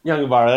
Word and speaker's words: Yungaburra: Though that meant going into the fifth and final stage, the Criterium around Yungaburra. Is that how you pronounce it? – Sure. Yungaburra, that sounds a lot closer than what I've been saying.